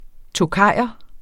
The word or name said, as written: tokayer